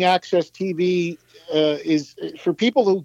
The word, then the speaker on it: AXS: And so for people who get Access TV, which is spelled AXS, It's pretty much the last standing music-centric channel on TV.